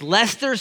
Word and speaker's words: Leicester: And what they did, this little team from Leicester City, don't Google it because it's not spelled L-E-S-T-E-R like normal English speaking people would do. It's L-E-I-C-I, whatever, whatever, whatever. I was rebuked by our staff, our soccer loving staff this week for not pronouncing it correctly. So it's Leicester City did what they had never done in their 130 plus year history.